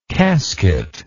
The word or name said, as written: casketlike